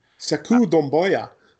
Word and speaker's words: Sekou Doumbouya: Sekou Doumbouya.